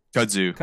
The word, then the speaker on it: kudzu: Kudzu.